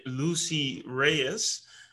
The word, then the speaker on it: Lucy Reyes: Today, I'm here with Lucy Reyes.